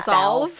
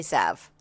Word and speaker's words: salve: I don't think you say solve. I think you say salve. Hand salve.